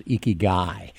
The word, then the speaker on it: ikigai: One of the words they do have, though, and a word that you like a lot is ikigai. Tell us about that. – Sure. Ikigai, which is spelled I-K-I-G-A-I, is a Japanese term that means the reason you get up in the morning.